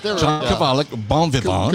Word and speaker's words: bon vivant: The T would not be pronounced in French. Bon vivant.